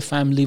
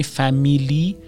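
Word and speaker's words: family: اولا دقت داشته باشید که فامیلی، اون آی که توی فامیلی وجود داره رو تلفظ نمی کنیم، یعنی فامیلی نمیگیم، میگیم فامیلی. ببینید انگار که اصلا آی وجود نداره.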